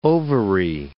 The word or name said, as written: ovary